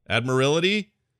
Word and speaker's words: Admiralty: Admiralty? I'm saying that right, aren't I? Admiralty. Because you're Admiral. Admiralty. – Admiralty. – Admiralty. Admiralty. Admiralty. You're right. It's Admiralty.